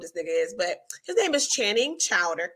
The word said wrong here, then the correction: Crowder: crowder crowder look i pronounced it wrong crowder there is a there was a clip circling around again, men with podcasts.